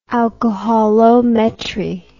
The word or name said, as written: alcoholometry